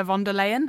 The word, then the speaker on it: von der Leyen: And when I think of the EU, actually, the first people, even more than Ursula von der Leyen, have I said that right? – Yep.